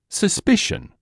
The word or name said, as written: suspicion